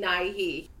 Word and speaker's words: Nighy: I've, I've always pronounced his last name Nighy.